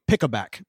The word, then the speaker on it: Picobac: P-I-C-O-B-A-C. – Okay. – Picoback. And I mean, I'm mispronouncing it, but that's what. – Imagine that. – I know, right? but to be honest, it's actually probably pick-a-back because the tagline is the pick of tobacco. Pick-a-back.